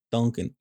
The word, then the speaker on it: Dunkin': the snowstorms and the angry people at Duncan. – Look. You know? There's no Dunkin' Donuts, all right, in San Jose. That's Duncan. the problem. You can pronounce it correctly, please. Dunkin' Donuts.